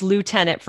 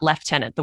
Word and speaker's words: lieutenant: That's lieutenant for all the Americans listening, not lieutenant, the way the word is actually pronounced.